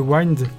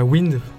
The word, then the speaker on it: Wind: album qui s'appelle Wind, euh, Wind, pour prononcer correctement.